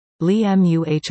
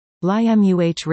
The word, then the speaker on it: Lima: Bonnie contributed several Ohioisms for us to consider, including, Cadiz, Spain, Cudiz, Ohio, Kadiss, Lima, Peru, Limuh, Ohio. Limuh. Rick writes in with this pronunciation story.